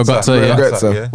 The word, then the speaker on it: Götze: How do you say his name? Yeah, Mario Götze.